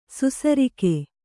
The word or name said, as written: ಸುಸರಿಕೆ